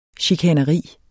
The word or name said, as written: chikaneri